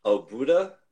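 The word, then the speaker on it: Óbuda: Because I'm looking at, it says Vasas, I'm probably going to butcher this, Obuda, O-B-U-D-A? – Yes.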